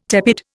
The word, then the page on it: tepid: Indian